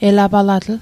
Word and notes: elapalatl: Mi'gmaq

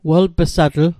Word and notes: welp'satl: wel'pîsatl; Mi'gmaq

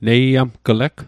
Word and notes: neiamgleg: Mi'gmaq

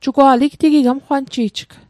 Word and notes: igtig: Mi'gmaq

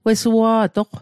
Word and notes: wesua'toq: Mi'gmaq

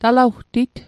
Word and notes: talawtit: Mi'gmaq

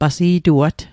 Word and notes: pasi'tuat: Mi'gmaq

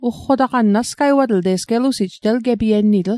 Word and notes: ugqotaqann: Mi'gmaq

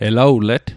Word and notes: elawlet: Mi'gmaq

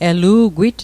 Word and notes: elu'gwit: Mi'gmaq

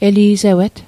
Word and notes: eli'sewet: Mi'gmaq

